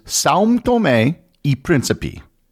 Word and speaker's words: São Tomé e Príncipe: The proper pronunciation in Portuguese, which is the national language, is São Tomé e Príncipe.